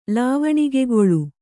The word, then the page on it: ಲಾವಣಿಗೆಗೊಳು: lāvaṇigegoḷu